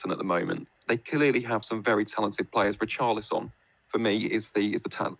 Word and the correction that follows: Richarlison: I don't know why I said it like that. Richarlison? Richarlison is the talisman. I've pronounced it like he's some Serie A player, but Richarlison is their talisman.